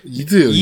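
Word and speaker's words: id: 다 알고 계시는데 이드예요. 이드예요.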